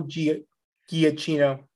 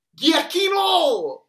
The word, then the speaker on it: Giacchino: Another thing I love, love, love about this scene that we should mention, the score by Michael Giacchino. – Giacchino!